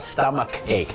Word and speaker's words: stomach ache: stomach ache c h อาจจะเป็นชอช้างแต่ c h ก็เป็นคอควายก็ได้นะครับ stomach ache นั่น